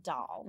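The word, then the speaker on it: Dol: On YouTube said that in the audiobook, the dog's name is pronounced Doll. Mm. – Yes. I wouldn't know that, but... Yes, that's how it's pronounced.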